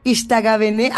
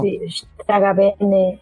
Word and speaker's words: Ixtagavene: Coordinadora de Ixtagavene. ¿Así lo pronuncié bien? No. – Ixtagavene.